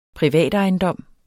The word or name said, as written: privatejendom